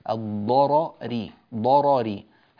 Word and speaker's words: الضرر: الضرر الراء الأولى مفتوحة، الراء الثانية مكسورة، لا موجب لترقيق الراء الأولى فتبقى على تفخيمها، وهنا يوجد يعني ثقل في النطق قليلا في تفخيم الراء الأولى ثم ترقيق الراء الثانية، الضرري ضرري هكذا تنطق، وبعض الناس يفخم الراءين أو يرققهما وهذا غير صحيح، فهذا يحتاج إلى دربة وتمرين.